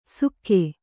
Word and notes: ಸುಕ್ಕೆ: sukke

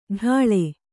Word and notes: ಡಾಳೆ: kannada

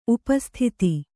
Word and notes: ಉಪಸ್ಥಿತ: upa sthita

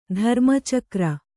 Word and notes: ಧರ್ಮಚಕ್ರ: dharma cakra

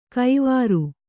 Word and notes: ಕೈವಾರು: Kannada

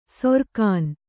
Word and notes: ಸೊರ್ಕಾನ್: sorkān

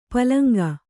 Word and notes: ಪಲಂಗ: palaŋga

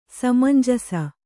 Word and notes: ಸಮಂಜಸ: samanjasa

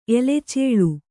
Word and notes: ಎಲೆಚೇಳು: Kannada

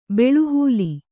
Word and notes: ಬಲೆಹುಲ್ಲು: Kannada

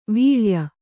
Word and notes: ವೀಳೆಯ: vīḷeya

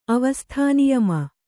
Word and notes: ಅವಸ್ಥಾನಿಯಮ: avasthāniyama

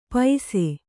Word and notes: ಪೈಸೆ: kannada

